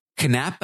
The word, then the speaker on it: Knapp: Next, we got Drew Knapp, I think is how you pronounce it. – Yeah, it's a K, but you pronounce the K.